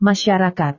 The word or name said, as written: masyarakat